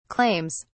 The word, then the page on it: claims: British English